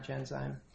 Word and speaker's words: Genzyme: Did I get the name right, Genzyme? – Yes, Your Honor.